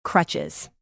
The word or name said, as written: crutches